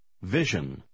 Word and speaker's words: vision: Однако в комбинации S-I-O-N она произносится как Жен. – Попробуйте прочитать номер 11. – He has a clear vision. vision.